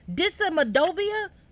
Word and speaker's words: dysautonomia: Dysautonomia, adoba. What did you say? – Dysautonomia. – Like onomatopoeia, but spell it for me. Dysautonomia. So it's dysautonomia. – Yes, yeah. Dysautonomia. – D-Y-S-A-U-T-O-N-O-M-I-A.